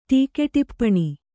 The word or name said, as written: ಟೀಕೆಟಿಪ್ಪಣಿ